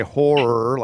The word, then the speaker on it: horror: I would say horror, like H-O-R-R-O-R.